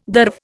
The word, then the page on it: derv: Indian